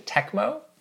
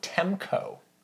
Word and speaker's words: Tecmo: I also used to pronounce this is like just dumb on my part, the company Tecmo. I used to call them Temco. – Oh, that you had like a, you transpose the letters. – But it's spelled T-E-C-M-O, so it's very obvious, like Tecmo.